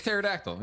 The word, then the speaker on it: pterodactyl: Like a pterodactyl. Yeah, the P silent.